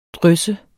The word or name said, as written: drysse